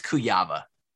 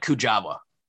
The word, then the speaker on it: Kuyava: I believe it's Kuyava. – Oh, okay. – It looks like Kujava. – Yeah, that's what I always said. – It's Kujuwa, I think. I don't know. – But I pronounced the hard J.